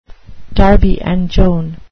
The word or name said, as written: Darby and Joan